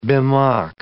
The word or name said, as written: bemock